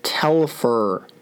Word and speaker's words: Telfer: Tefler. Tefler Telfer. Telfer. I'm sorry, I can't say that name. That's like, yeah, that's a six letter word. Why can I? Okay, Telfer. That's how you say it.